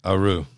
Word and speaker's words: Aru: So I opened it up and played it, and he was telling me that you got it right, and that's how you pronounce it. – Aru.